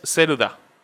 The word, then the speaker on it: Zelda: Yo le digo eh, Zelda porque Zelda en japonés, Ceruda. Entonces, es básicamente que cambiaron la última sílaba de Cerude.